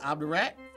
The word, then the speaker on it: obdurate: Is it E silent? Obdurate? Obdurate. – Obdurate. So it's not even an R-A-T-E, it's a rat. – R-A-T, man. Fucking silent E's, bro. Silent letters, man.